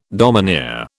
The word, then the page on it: domineer: British English